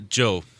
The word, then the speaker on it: Zhou: And it's spelled Z-H-O-U. – That's right. As long as you don't say Zhou, it's okay. – Okay. Why? Is that a bad word? – Well, no. It's just, that's. I think that's the most common mispronunciation.